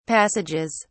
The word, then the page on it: passages: British English